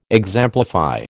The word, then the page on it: exemplify: American English